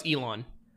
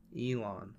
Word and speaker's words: Elon: I'm talking Elon Musk. Is that how you say his name? – Yeah. – Oh no, it's Elon. – Elon.